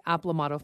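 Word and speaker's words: Aplomado: Okay, so I hope I'm saying it right, but what is the Aplomato falcon? – You've got it right.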